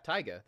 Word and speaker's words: Taiga: and that's Taiga. Am I saying that right? T-A-I-G-A. – Yeah, Taiga, that's actually correct.